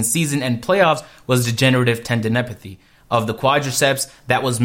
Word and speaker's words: tendinopathy: I definitely would have um, not said that correctly. Ten, tendinopathy.